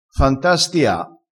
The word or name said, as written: fantastia